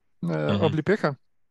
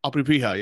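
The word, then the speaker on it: Oblipeka (обліпиха): My favorite is one I can't pronounce, Obliprinchka. – Oblipeka. – Oblipeka, yes.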